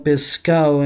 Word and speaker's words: Pescado: A banda foi formada em Cali, mas seus membros são do departamento de Chocó, onde, devido a um sotaque característico, eles costumam cortar a letra S e outras mais, como nessa canção que escutaremos a seguir, chamada Pescado Envenenado. Na pronúncia deles fica Pescao Envenenado.